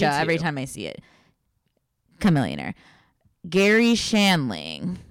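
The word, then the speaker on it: Chamillionaire: Chameleonaire.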